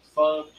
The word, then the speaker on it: pho: I think it's the Nguyen Pho Grill. Pho, sorry. Still getting a little bit used to the pronunciations.